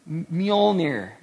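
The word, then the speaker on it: Mjölnir: It's Mjolnir. It's MJ. Except you said no, it's Y. Say it again. Mjolnir.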